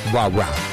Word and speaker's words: Wawa: Steve Trevelise, not Wawa, Wawa. Wawa. Johnny Watson with the rah-rah. – The accent is on the rah. The second wah. – Yes, the first, as opposed to the first wah.